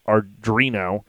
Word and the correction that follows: Arduino: And it's an open source. – Arduino. Yeah, Arduino.